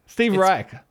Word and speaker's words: Reich: Steve Reich.